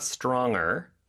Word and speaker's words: stronger: And the pronunciation of that G changes a little bit. It's not stronger. – No. – It's stronger.